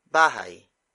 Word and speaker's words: bahay: Bahay. Bahay. Bahay is spelled as B-A-H-A-Y.